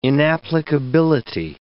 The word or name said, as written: inapplicability